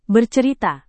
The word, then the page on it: bercerita: Indonesian